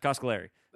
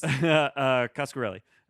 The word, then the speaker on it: Coscarelli: Coscarelli. – So close. – Coscarelli.